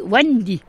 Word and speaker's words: Wendy: Il y a Vondi aussi, mais Vondi, V-W, E-N-D-Y. Mais normalement, ça se dit Wendy. Voilà, ça fait comme les Anglais, ils prononcent le W, comme William.